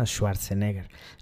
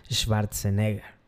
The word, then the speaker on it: Schwarzenegger: no se pronuncia Schwarzenegger, Schwarzenegger. Pasé tres meses en, Austria y bueno, cualquier cosilla me acuerdo.